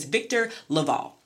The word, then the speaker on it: LaValle: So this week he is a black man, but I will put some respect on his name by pronouncing it correctly. His name is Victor LaValle.